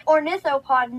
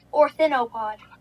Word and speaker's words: Ornithopod: Fun fact, it's pronounced Ornithopod, not Orthinopod.